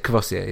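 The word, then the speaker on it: Courvoisier: it's Kvossier, no? – Yeah, it's Kvossier, yeah.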